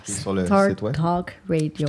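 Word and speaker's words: StarTalk Radio: Et ce qui est intéressant, je vous conseillerais d'aller l'écouter, donc le Cosmic Queries du podcast Star Dark Radio. – On va mettre le lien parce que c'est mal prononcé là, sur le Facebook. – Star Talk Radio.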